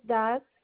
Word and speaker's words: Das: Last name is Das, DAS.